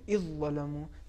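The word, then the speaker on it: اذ ظلموا: مثال کے طور پر دیکھیں سورہ نساء میں آیت نمبر 64 میں استعمال ہو رہا ہے اذ ظلمو، صحیح تلفظ یہ ہے اذ ظلمو، یعنی دال کو بالکل حذف کر دیا جائے گا اور دال کی جگہ پر حرف ذو کو یا حرف ذا کو تشدید کے ساتھ جی.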